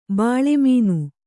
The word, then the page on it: ಬೆಳುಮೀನ: beḷu mīna